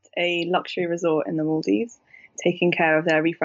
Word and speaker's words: Maldives: Maldives. – Maldives. – Yeah. – Like between an A and an O, Maldives.